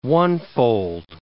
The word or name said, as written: onefold